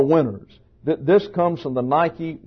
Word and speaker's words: Nike: N-I-K-E. And Actually, you see here, this is an eta. And an eta, pronounced in English, is like a long A. So it's nike. Nike. But the tennis shoe people have corrupted all of that, and we call it Nike.